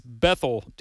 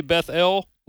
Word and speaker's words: Bethel: And I did want to ask you, when it comes to the how to pronounce Bethel, do you say Beth-El like some people, or Bethel like we Southern folks? – I think the original Hebrew probably is more Beth-El, but I'm from the South, so it's Bethel, right? – That's right.